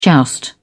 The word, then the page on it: joust: British English